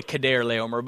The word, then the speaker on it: Chedorlaomer: In the days of Amraphel, king of Shinar, Arioch, king of Elasar, I love this one, Chedorlaomer, it's really Chedorlaomer, but Chedorlaomer, king of Elam,